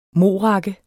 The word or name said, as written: morakke